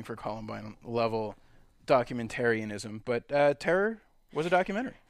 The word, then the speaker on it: (T)error: We saw Terror. T-Error. – T-Error? Yeah, I wasn't sure how I was going to pronounce that one.